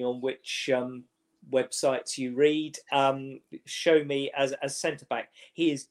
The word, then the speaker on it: Sowunmi: It's spelt Sowonmi, Sowunmi, but it's pronounced Show Me.